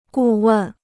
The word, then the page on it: 顾问: gù wèn